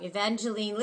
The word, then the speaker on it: Evangeline: Um, Evangelo, oh god, I had her name wrong. Evangeline Lilly is in it.